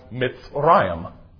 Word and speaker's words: Mitzrayim: Egypt in Hebrew is pronounced Mitzrayim.